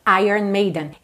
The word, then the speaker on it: Iron Maiden: Iron Maiden.